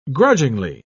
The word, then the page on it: grudgingly: British English